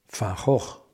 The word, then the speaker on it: Van Gogh: Entonces, eh, de mucho de lo que hizo Van Gogh, Van Gogh, para ser correctos en la pronunciación,